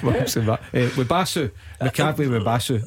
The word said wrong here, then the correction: Mubarak Wakaso: Uh, um, wow, you butchered that. Mubarak Wakaso. Aye, that's right.